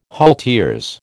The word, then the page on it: halteres: American English